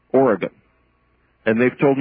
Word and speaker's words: Oregon: And listen, did I say Oregon in the approved way or not? – Pardon? – How do you say it? Say Oregon. – Oregon? – Say it the way you'd say it, so you wouldn't get slapped around. – Just Oregon.